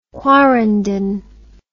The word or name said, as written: quarenden